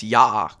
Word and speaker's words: Yaak: I think it's pronounced Yak. – Is it Yak? – There's two A's in it. It's spelled Y-A-A-K.